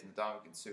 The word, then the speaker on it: Ndamukong Suh: all you have to say is Ndamukong Suh, and you know that it's ugly. Like, nice pronunciation. – Ndamukong Suh.